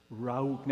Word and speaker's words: Rauch: Rauch. Now, if you're not kind of coughing something back up here, you're not saying it right. This isn't Rauch. It's Rauch. Hear it.